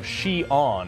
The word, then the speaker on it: Xi'an: All right, COVID lockdowns in China as cases surge in the central city of Xi'an.